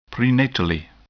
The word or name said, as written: prenatally